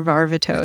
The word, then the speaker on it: Varvatos: Varvatos, goddamn, I always say that wrong. Wait, and I will until I die. – Is it Varvatos or Varvatos? – It's Varvatos.